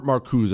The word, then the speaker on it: Marcuse: Herbert Marcuse is how you say his name, I believe.